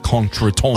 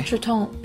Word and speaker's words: contretemps: It is contretemps. – Oh. – It's got that real silent P and S on the end of it. – Okay. – Contretemps.